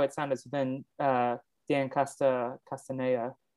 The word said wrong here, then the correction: Castellaneta: is that how you say his name castellaneta castellaneta uh,